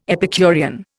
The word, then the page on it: Epicurean: Indian